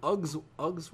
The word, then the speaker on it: UGG: Yo, it's not pronounced Uggs, bro. It's UGG. – Uggs. – Go anywhere else Uggs. In Europe or where the actual company comes from. UGG. It's UGG. Yeah.